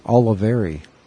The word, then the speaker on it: Oliveri: Is it Olivieri or Olivier? – Oliveri. – Oliveri.